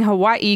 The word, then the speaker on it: Hawaii: You are saying Hawaii correctly. The Hawaiian language pronounces every letter.